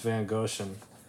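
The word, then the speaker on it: Wegrzyn: I really had to look it up because it's spelled W-E-G-R-Z-Y. – German. – Yeah. – Yeah. – It's pronounced Van Goshen.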